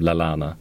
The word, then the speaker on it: Lallana: Så här, I'm Adam Lalana blev jag besviken på honom.